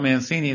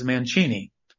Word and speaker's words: Mancini: By the way, speaking of the name, when I was in Italy, the Italian people there told me that I don't even know how to pronounce my own last name. It's not Mancini. They said it's Mancini.